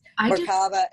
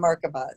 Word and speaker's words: Merkaba: I hear it both ways, Merkaba and Merkaba. Depends on who you're talking to.